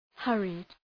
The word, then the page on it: hurried: ‘hɜ:rıd; ‘hʌrıd